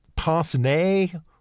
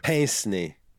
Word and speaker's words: pince-nez: How do you say it? Pince-nez or? – Pince-nez. – Pince-nez? – Yeah, pince-nez.